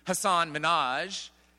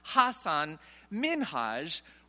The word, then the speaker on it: Hasan Minhaj: And he explained, well, most people actually call him, and maybe you and I do too, Hasan Minhaj. But he carefully told the audience, and Ellen in particular, it's actually pronounced Hasan Minhaj, reflecting his Indian heritage and his Muslim descent.